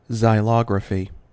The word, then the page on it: xylography: American English